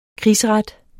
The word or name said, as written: krigsret